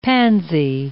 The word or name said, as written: pansy